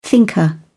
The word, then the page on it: thinker: British English